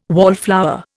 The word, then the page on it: wallflower: Indian